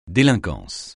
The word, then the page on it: délinquance: français